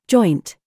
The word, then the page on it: CHOICE: British English